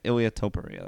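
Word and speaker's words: Ilia Topuria: Ilya Topuria. That's how you say it.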